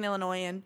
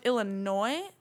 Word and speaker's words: Illinois: Just want to say, it is pronounced Illinois, not Illinois. – I don't know. – It's just a pet peeve I wanted to put out there, because everyone says Illinois, like the S on the end has a sound.